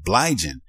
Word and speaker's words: Bligen: His name is spelled, last name is spelled B-L-I-G-E-N. Blygen is how you pronounce it.